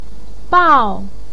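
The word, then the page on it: bao4: tone 4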